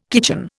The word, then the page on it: kitchen: Indian